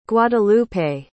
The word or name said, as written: guadalupe